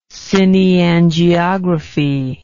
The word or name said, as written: cineangiographic